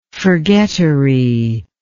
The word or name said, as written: forgettery